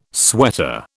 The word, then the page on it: sweater: British English